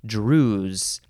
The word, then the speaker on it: Druze: This episode is starting with the word Druze. I think that's just how you say it. Now, you can spell it capital D-R-U-Z-E or an S instead of a Z.